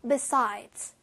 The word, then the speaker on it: besides: besides. S 발음 마지막에 꼭 챙겨주세요.